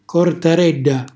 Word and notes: cortarella: calabrese

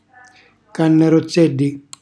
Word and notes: cannarozzelli: calabrese

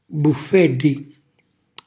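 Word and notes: buffelli: Calabrian

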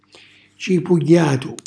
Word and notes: cipugghjatu: calabrese

